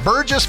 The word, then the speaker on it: Burgess: Burgess. Almost put a hard G on that. Burgess, Virginia, Maryland, given a $500 single gift.